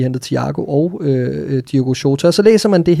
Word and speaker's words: Diogo Jota: Lad os, lad os lige få noget øh, udtaleservice på her, fordi øh, du, du læste et sted, hvordan man skulle udtale det ordentligt. Diogo Jota. Mm-hmm. Diogo Jota.